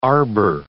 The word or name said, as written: arbor